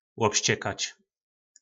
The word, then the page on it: lobscekac: po nasimu